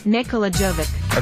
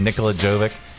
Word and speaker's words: Nikola Jovic: Nikola Jovic. – Okay, Nikola Jovic.